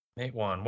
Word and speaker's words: Matewan: I guess it's Matewan. That's how you pronounce it.